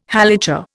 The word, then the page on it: Halacha: Indian